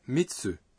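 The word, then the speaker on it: mitsu (三つ): La façon de prononcer certains nombres change lorsqu'ils ont la composante tsu, n'est-ce pas? – En effet, 3 c'est sam. – Mais s'il est suivi de tsu, il devient mitsu.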